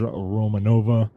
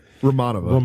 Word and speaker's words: Romanova: There he finds Romanova in bed waiting for him. – Your streak of mispronouncing names continues. – Yeah. What is her name? – Romanova.